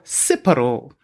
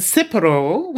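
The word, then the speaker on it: tsipouro: It's called O Purist, and it's an anagram for Cipro, which is, it's a Greek spirit.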